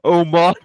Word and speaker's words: Omos: You know, I mean, obviously we've seen Amos, whatever his name, Omos. Is it, how do you properly say his name? Wow. So I have to say his name is Omos. That's how you properly say it.